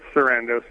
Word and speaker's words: Sarandos: It's Sarandos. And Sarandos.